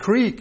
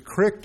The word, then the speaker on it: creek: Both pronunciations are in there. Creek is the first one, but crick is also in there.